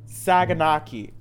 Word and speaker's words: Saganaki: Saganaki. Is that how you say it, Courtney? – Yes.